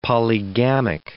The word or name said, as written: polygamic